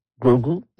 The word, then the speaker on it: Grogu: Mais avant ça tout de même, avant le 50e anniversaire, il y aura le film Star Wars, The Mandalorian and Grogu. Est-ce que je le dis bien? – Grogu? – Ouais, oui.